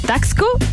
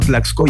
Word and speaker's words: Taxco: Eh, digamos, se escribe Taxco, pero acá la gente habla de Tlaxco. Le ponen una L entre el medio, lo pronuncian como Tlaxco